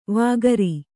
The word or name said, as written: ವಕ್ರೆ